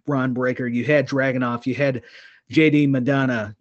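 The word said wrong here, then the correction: McDonagh: you know, or how do you say that name? Gosh, I can't even say it. – McDonough. McDonough.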